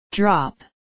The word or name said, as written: drop